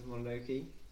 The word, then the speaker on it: Mononoke: I think I enjoy it because it's a little bit lighter than Princess Mononoke.